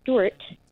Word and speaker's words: Stewart: Okay, so it is Kimberly Stewart. Kimberly is spelled K-Y-M-B-E-R-L-Y, and Stewart is S-T-E-W-A-R-T.